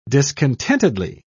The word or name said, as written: discontentedly